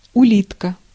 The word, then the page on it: улитка: /ʊˈlʲitkə/; ulitka